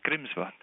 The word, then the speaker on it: Grímsvötn: It looks like this one's pronounced Grímsvötn. It's a shorter word to pronounce. – Grímsvötn? – Grímsvötn.